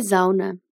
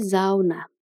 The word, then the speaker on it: Sauna: Die Sauna, podemos presumir que é sauna. Porém, tem uma leve diferença na pronúncia do S. Vamos treinar só essas palavrinhas. Die Sauna. Die Sauna.